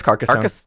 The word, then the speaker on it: Carcassonne: It's Carcassonne. We had a friend in high school who acted really bold about the idea that they knew how to properly pronounce this, and they said, it's Carcassonne. And then later we realized that's absolutely not true, and nobody says Carcassonne.